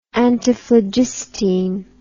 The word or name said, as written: antiphlogistine